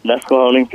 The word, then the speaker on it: Nesquehoning: You live, and I'll probably mispronounce this, Nesquehoning, PA? – Yep, Nesquehoning, PA, yep.